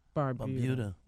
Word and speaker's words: Barbuda: Barbuda.